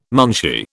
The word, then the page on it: munshi: British English